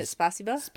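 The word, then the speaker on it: spasiba (спасибо): Well, it's like saying thank you in Russian, but getting it wrong. Space spa. – Isn't it spasiba? – Spasiba. – Spasiba, excuse me. – Spasiba. – I had the word right, but the pronunciation wrong.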